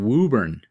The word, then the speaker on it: Woburn: The event circles a lake in Wakefield, Massachusetts, not too far from where I used to work in Woburn, Massachusetts.